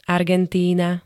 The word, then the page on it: Argentína: [-t-]